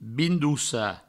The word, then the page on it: bindossar: Provençal; bindoussa